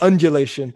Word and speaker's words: undulation: And I'm probably going to butcher this, but this idea of unulation, did I say it right? – You forgot the D, but unulation sounds pretty good too. – Okay, this idea of undulation.